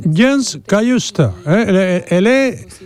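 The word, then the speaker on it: Cajuste: But I say Jens Cayuste is how I would say it.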